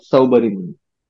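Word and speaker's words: Saubhari Muni: And I feel like the emblem of that that we popularly hear about is his name, Subari Muni or Subari Muni? How do you say? – Saubari Muni.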